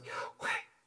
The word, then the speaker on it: Yahweh: We say Yahweh, but in ancient Hebrew it's pronounced Yahweh, wind.